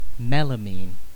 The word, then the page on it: melamine: British English